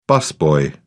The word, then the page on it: busboy: British English